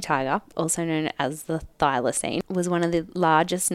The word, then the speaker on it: thylacine: But these are just like the facts, right, about the thylacine. Is that how you say it? – I think so. I've always said it that way.